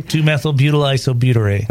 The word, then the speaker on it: 2-methylbutyl isobutyrate: 2-methylbutyl isobutyrate.